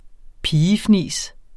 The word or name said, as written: pigefnis